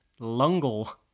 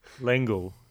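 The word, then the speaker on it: L'Engle: This episode, we've scanned for life between the pages of A Wrinkle in Time, a sci-fi written by Madeline Lungle. Is that how you say it? – That's my best attempt. – Madeline Lengel? – Lengel. I think it's Lengel. Lengel.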